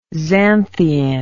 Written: xanthein

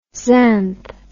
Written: xanth

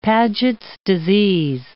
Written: Paget's disease

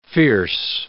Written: fierce

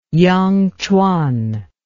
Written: yangquan

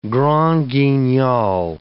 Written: Grand Guignol

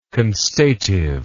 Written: constative

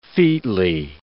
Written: featly

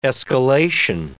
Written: escalation

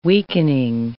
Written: weakening

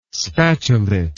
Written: spatulate

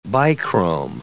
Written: bichrome

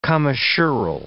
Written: commissural

